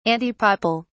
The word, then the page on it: antipapal: British English